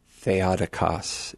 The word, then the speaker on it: Theotokos: The Theotokos, the Mother of God. Or actually, technically, it's Theotokos, if you're going to pronounce it properly.